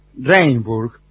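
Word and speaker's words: Rijnsburg: Hablando de entidades, sí, hace algunos años que vivo en Ringsburg, se pronuncia Rainburg, no lejos de La Haya.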